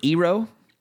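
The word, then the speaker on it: gyro: So number 18, I know this causes quite a stir with how you pronounce it. I pronounce it Eero. I've heard them called gyros. I've heard them called gyros. – Hero. – I've heard them called, yes. I don't even fool with the first letter. I just say Eero. – Hero. – And the ever popular silent G.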